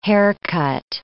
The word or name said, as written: haircut